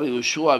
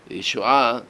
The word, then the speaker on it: יהושע: כי האשכנזים לא מבטאים את העין. הם יגידו, יהושע, נשמע באוזנם כמו אותו האיש. הרי את העין לא מבטאים, יהושע, יהושע, יהושע זה מזכיר את אותו האיש.